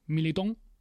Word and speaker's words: Militão: Dicen los portugueses que es Militão.